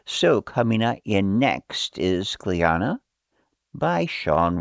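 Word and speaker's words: Clíona: The one that's coming up next is entitled Cliona, which is or it can also be pronounced Cliona, depending on what part of Ireland I guess you're from.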